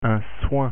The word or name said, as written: soin